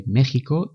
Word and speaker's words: México: Esto se produce en los topónimos de México y Texas, que se escriben con X, pero se pronuncian como si fuera una J.